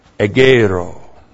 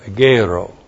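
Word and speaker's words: Egeiro (ἐγείρω): And then we have Egeiro. E-G-E-I-R long O. Egeiro.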